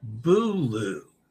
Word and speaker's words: Boulud: Boulud. That's how you pronounce his name.